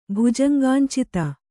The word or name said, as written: ಭುಜಂಗಾಂಚಿತ